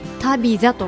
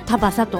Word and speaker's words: Tabitha: タビザと書いてタバサと読みます。